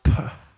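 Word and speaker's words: Pugh: It's actually, this is how you pronounce her last name. Florence Puh.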